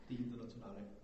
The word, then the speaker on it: Die Internationale: Die Internationale.